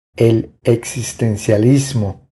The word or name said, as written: El existencialismo